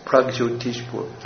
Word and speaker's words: Pragjyotishpur: But he goes to Pratyek Joshpur. Is that saying it right? Pronounce it? Pratyek. – I always thought it was Pragjyotish. – Huh? – I always thought it was Pragjyotishpur. – Pragjyotishpur, correct.